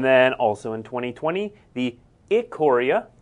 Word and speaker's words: Ikoria: So we haven't yet announced the pre-release or release dates for Theros Beyond Death or Ikoria. – Yep. – You may not have heard it pronounced that way before. I was told immediately before the show it is Ikoria.